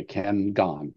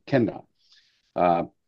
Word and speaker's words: Ken Gon: And it's pronounced that way, Ken Gon. It's like Ken Gon.